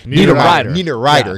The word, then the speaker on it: Niederreiter: You know, Niederreiter. Did I pronounce his name? – Niederreiter, yeah.